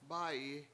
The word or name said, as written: bA7yi